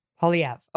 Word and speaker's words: Poilievre: Poilievre. – Okay, Pierre Poilievre.